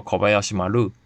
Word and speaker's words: Kobayashi Maru: 那么我再举一个例子，星际舰队著名的星舰小林丸号，日语读作 Kobayashi Maru。小林丸这三个汉字，其中小训读作 ko，林训读作海亚西，林接续在小字之后，词首辅音哈就弱化为浊音巴，于是小林就是 Kobayashi，小林丸则是 Kobayashi Maru。